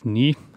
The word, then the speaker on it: Ng: Andrew Ny, skrives eh, etter han skrives NG, men en kinesisk kollega av meg har fortalt at det uttales Ny.